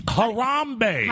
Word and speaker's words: Harambe: Columbe? – Harambe. – How do you spell that? – H-A-R-A-M-B-E. – H-A-R-A-M-B-E? – Yeah, A-M-B-I-E.